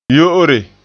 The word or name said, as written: yo'ore